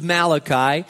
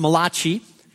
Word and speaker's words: Malachi: Malachi, the entire four chapters of Malachi, by the way, it's not pronounced Malachi. It's Malachi, and he was a prophet,